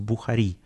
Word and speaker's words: Бухари: А в декабре, 23 декабря будет большой лайнап в Бухаре, здесь в Бухари, э, в грузинском э, надо ставить ударение на каждом слог, Бухари.